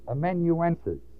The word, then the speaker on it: amanuensis: This Tertius, of verse 22, who wrote this epistle, in your reading from time to time, you're going to run across the word that is pronounced amanuensis. It is spelled A-M-A-N-U-E-N-S-I-S.